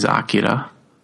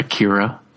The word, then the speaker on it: Akira: Well, the correct pronunciation is Akira, not Akira. It's Kanada, not Kaneda.